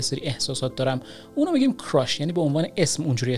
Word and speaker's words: crush: برای قسمت بعدی میخوام که درباره کلمه crush صحبت کنم که توی فارسی هم ازش خیلی زیاد استفاده میشه، یعنی با تلفظ اشتباه کراش خیلی ها اینو استفاده میکنن که crush در واقع تلفظ باید بشه، نباید بگید کراش، که در واقع این تلفظ فارسی این کلمه هست.